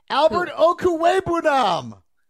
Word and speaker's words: Albert Okwuegbunam: Albert Okwuegbunam.